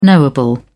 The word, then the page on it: knowable: British English